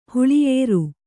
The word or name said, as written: ಹುಳಿಯೇರು